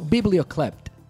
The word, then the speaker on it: biblioclept: Biblioclept. Biblio. ¿Y se pronuncia biblio o biblo? No, no, biblio. Biblioclept.